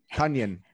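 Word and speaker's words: Tonyan: It doesn't have a weird pronunciation now that he just decided that. – Rhymes with Funyan. – Tanyan.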